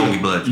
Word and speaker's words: Youngblood: É, que é a trinca que eu gostaria de falar, que vem Idols e é Wingblood, é assim que se é, pronuncia. Youngblood. Youngblood.